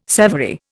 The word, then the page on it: severy: Indian